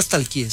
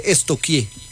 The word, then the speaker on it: stalkeé: Porque no se dice nunca stalkeé, se dice estoquié. – ¿Estoquié? – Sí. Pregúntele a la profe, verá. A mí una vez estamos estoqueando. – Sí, estoqueando. Yo, pero se escribe stalkear. Es estoquear.